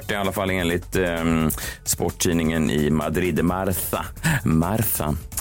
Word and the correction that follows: Marca: Är det så man uttalar det, John? – Marca, tror jag.